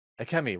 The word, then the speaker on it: Akemi: And then the very end of the episode, I really like too, where it reveals that Amiko, or did Akemi. I get the name right? Akemi, right. Akemi is in the Shogun's palace.